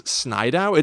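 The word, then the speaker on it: Snidow: I like that his last name is Snydow. It's S-N-I-D-O-W. – Okay. – Which sounds like if a toddler was pronouncing the name Snyder.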